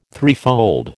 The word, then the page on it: threefold: American English